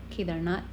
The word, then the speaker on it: Kedarnath: Am I saying that right? – Kedarnath, yeah.